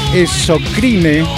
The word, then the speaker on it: Exocrine: Vamos a pasar al plano internacional con la presentación de esta banda llamada Exocrine, se escribe con X, Esocrine, así lo pueden buscar.